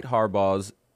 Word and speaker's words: Harbaugh: Jim Harbaugh. And Michigan will face Washington for the title despite Harbaugh's – is that how you say his name? Harbaugh? – Harbaugh's yep.